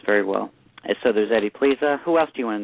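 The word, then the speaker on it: Plesa: It's my lucky day, Edward Plessa. – Eddie Pleza. It's pronounced Pleza.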